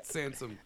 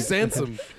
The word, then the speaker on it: Sansom: So don't even start with your mispronunciations of Samson. – God damn. – There's no P anywhere near it. It's not even in street. Samson Streep. That's Meryl Streep's Meryl brother, Streep. Sansom. Sansom Streep.